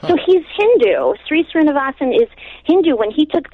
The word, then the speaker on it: Sri Srinivasan: Yeah, Sri Srinivasan.